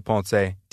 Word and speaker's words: Ponce: I'm at Anthony Ponce on Facebook and Anthony Ponce TV on Instagram. And my last name is spelled P-O-N-C-E.